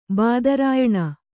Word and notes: ಬಾದರಾಯಣ: bādarāyaṇa

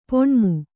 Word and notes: ಪುಣ್ಣಮೆ: Kannada

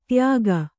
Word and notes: ದಾಯಿಗ: dāyiga

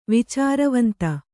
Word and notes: ವಿಚಾರವಂತ: Kannada

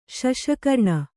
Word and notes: ಶಶಕರ್ಣ: kannada

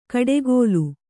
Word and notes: ಕಡೆಗೋಲು: kaḍegōlu

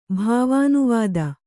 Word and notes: ಭಾವಾನುವಾದ: bhāvānuvāda

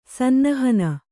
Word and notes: ಸನ್ನಹನ: Kannada

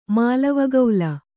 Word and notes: ಮಾಲವಗೌಲ: mālava gaula

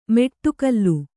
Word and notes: ಮೆಟ್ಟುಗಲ್ಲು: Kannada

